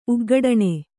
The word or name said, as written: ಉಗ್ಗಡಣೆ